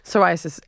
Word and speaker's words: Psoriasis: Psoriasis. S O R I Y S-I-S. That was terrible. I'm sorry to anyone that has it if I've offended you. – It's actually P-S. It's a silent P.